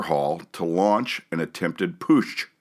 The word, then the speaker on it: Putsch: How's it, how do you pronounce that? Damn it. – Putsch. – Putsch. Is that right? – Putsch.